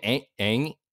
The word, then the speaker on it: Ng: But we have Kim Ng in the list. I want people to, it's spelled NG. You're going to be seeing something unfamiliar there, unorthodox there.